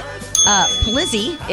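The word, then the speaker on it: Polizzi: Nicole Snooki Palizzi is 33. Did I say that right? I know you like that show, Jersey Shore. – Yeah, I think that's right.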